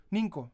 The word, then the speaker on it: Ninco: Bueno, ¿qué es Escalextrix y Nico? Ninco. Perdóname por equivocarme con la pronunciación.